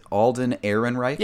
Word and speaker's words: Alden Ehrenreich: Alden Ehrenreich? – Yeah, Alden Ehrenreich.